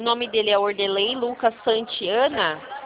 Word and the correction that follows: Santana: É Santana ou é Santiana mesmo? – É Santana.